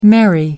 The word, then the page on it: marry: American English